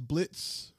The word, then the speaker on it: Blitzo: You have the boss, who's name is Blitz, but it's spelled B-L-I-T-Z-O, and the O is silent, and he makes sure you know that.